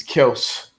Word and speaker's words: Kelce: Oh yeah, we are pronouncing Kelce's name. It's Kelce, apparently.